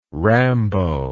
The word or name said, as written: rambo